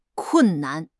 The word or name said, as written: kun4 nan5